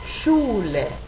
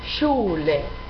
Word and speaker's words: Schule: La SCH se pronuncia como la SH en inglés. Por ejemplo, Schule, Schule. Es el mismo sonido, SCH, sh, sh.